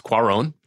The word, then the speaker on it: Cuarón: It's Cuarón.